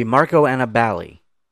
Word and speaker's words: Marco Aniballi: How do you pronounce his name? Because you're so good with your New York accent. – So he confirmed it should be Marco Aniballi.